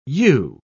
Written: yew

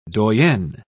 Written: doyenne

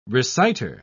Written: reciter

